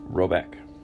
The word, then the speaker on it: Roback: The manor house, or Herregard, in question is called Roback, pronounced something like Robeck, though that is not its name.